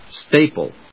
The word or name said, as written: staple